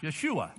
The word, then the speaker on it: Yeshua: That word in the Hebrew is Joshua. – Esa palabra en hebreo es Josué. – Or, yeah. Or sometimes pronounced Yeshua.